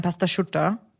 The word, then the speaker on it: Pasta asciutta: Nein, also Pasta Asciutta schreibt man das. Asciutta. Pasta Asciutta, aber man sagt dann Pasta Schutta. Das geht dann ineinander über.